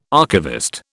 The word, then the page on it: archivist: British English